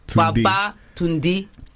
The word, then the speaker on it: Babatunde: Babatunde. – Tunde. So say it like you're saying come back or returned.